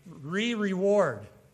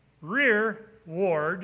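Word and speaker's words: rereward: Well, I, that's not how you pronounce that. I, I kind of figured that out. It's, it means rear guard. The rear, rear ward is behind us, you see? Not re reward. We're mispronouncing it. The Old English probably said rear ward, meaning rear guard, you know?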